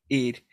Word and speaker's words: Eid: He gets invited by Kamala to Eid. Am I saying that right? – Eid.